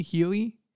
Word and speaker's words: Helly: How do they say it in the show? It's spelled Helly, H-E-L-L-Y. – Yeah, I think it is Helly.